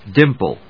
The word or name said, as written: DIMPLE